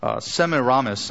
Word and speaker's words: Semiramis: His wife, Ceramis, was the high priestess of the mother-child religion. Semiramis, I mispronounced her name, Semiramis claimed that she was immaculately conceived and that her son Tammuz was the result.